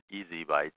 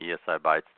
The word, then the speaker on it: ESI Bytes: You can also remember our show and website phonetically as Easy Bytes, which is how it sounds out of ESI information. However you choose to remember us, just come to ESI Bytes to learn more about eDiscovery before ESI bites you back.